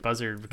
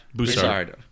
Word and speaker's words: Bussard: I always pronounce it buzzard. – Bussard. – Okay. – It's Bussard. Yeah, take it from the Canadian. He knows how to pronounce things.